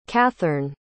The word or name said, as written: cathern